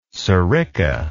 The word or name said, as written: sirica